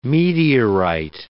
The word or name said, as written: meteorite